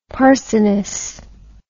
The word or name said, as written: parsoness